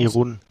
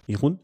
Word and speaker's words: Irun: Äh, dum, dum, dum, dum, Route Etappe Nummer 5 nach Bilbao von, ich sage mutmaßlich Irun, spricht man es aus? Irun. – Irun. Ähm, 187 Kilometer.